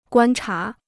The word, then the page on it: 观察: guān chá